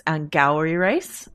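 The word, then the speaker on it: Angourie Rice: The great, we were mispronouncing her name, but I guess it's Angowri Rice.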